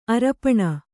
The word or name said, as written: ಆರೋಪಣ